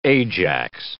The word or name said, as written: Ajax